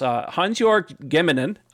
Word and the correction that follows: Gemmingen: did I pronounce that right? Gemmingen.